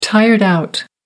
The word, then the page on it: tired out: American English